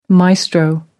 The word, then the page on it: maestro: American English